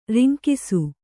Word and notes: ರಂಗಿಸು: rangisu

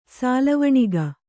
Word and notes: ಸಾಲವಣಿಗ: Kannada

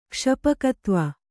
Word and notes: ಕ್ಷಪಕತ್ವ: Kannada

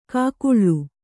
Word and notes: ಕೂಗಳಿ: kannada

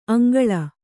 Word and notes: ಅಂಗಳು: aŋgaḷu

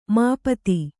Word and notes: ಮಾಪತಿ: māpati

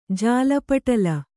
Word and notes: ಜಾಲಪಟಲ: jāla paṭala

